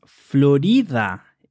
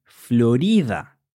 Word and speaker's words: florida: Well, actually, this adjective is pronounced Florida in Spanish, with the stress in the R, Florida.